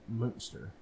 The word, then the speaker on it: Munster: No, it's pronounced like the cheese. – Transylvania. – Like Munster cheese. It's Munster.